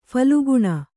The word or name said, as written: ಫಾಲ್ಗುನ